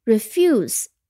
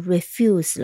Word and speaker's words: refuse: Refuse，我们注意它的名词形式，当它变成名词不做任何变化的时候呢，它的读音也变了，它的读音重音变到了第一音节，所以不读 refuse 了，读成 refuse。